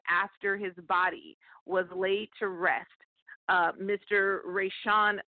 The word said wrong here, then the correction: Rayshard: Brooks, am I saying his name correctly? I want to make sure to say that. Rayshard, I apologize.